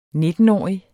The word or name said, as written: nittenårig